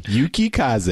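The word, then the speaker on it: Yukikaze: Yakukaze. I can't say Yuki Kaze. – Yuki Kaze. It's four syllables. Yuki Kaze.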